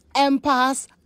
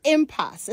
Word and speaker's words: impasse: I've heard people say impasse, impasse, impasse, but it's impasse. It's not clear where these alternative pronunciations came from, but there's only one correct way of saying it. – Impasse. I-M-P-A-S-S-E.